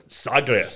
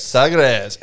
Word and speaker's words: Sagres: Sagres. – Sagres.